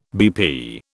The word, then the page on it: BP: British English